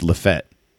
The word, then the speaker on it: Lafayette: If you're from Louisiana, it's Lafette. I have been told.